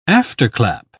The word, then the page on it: afterclap: British English